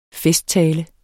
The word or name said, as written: festtale